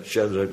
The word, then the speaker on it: Sadrach: Daniël 3, waar letterlijk zo'n beeld staat in Babel en waar Sadrach, Mesach en Abednego, ja, anders komt het met de muziek niet overeen, dus zo moet je het uitspreken, Sadrach, Mesach, Abednego.